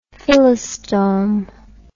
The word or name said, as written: phyllostome